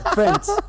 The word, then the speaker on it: Friends: Friends. – Sí, la I no se pronuncia.